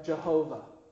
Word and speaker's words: Jehovah: Is a name that is sometimes, in our English, pronounced as Yahweh or Jehovah.